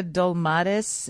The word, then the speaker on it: dolmades: It looks like a dolmades.